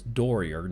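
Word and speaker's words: Dorier: I want to always pronounce it Doyer, but that's not the way he pronounces it. So it's Doyer, Nicholas Doyer,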